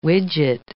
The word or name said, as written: widget